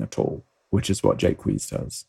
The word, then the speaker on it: Jaques: His name was probably pronounced as a single long syllable in Shakespeare's time, Jaques, which makes the name a homophone for a privy or a latrine.